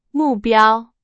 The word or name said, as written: 目标